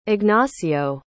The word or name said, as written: Ignacio